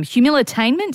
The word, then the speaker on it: Humilitainment: First off, what is this term? Humilitainment? Did I say it right? – Well, I think you have.